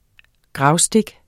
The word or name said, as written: gravstik